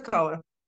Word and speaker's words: Petkova: Svetla Petkova.